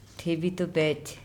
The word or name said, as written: ThebitobEc